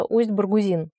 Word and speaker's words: Усть-Баргузин: В общем, идеальным вариантом, как мне виделось, было бы проехать от Улан-Удэ вдоль Байкала на восток, до места, которое называется Усть-Баргузин, кажется, на последний слог ударения.